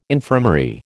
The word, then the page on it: infirmary: American English